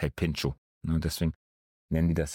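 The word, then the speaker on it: Pintxo: sondern dieses X wird ausgesprochen, deswegen Pincho.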